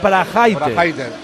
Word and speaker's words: Braithwaite: Pronuncia bien, Quique. La gente se llama como se llama. Este es Braithwaite.